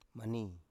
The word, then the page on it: mani: Biak